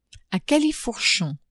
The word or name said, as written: a califourchon